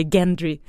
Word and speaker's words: Gendry: Men jag tycker att Gendry ska heta Gendry för det är så det stavas, även om de säger Gendry.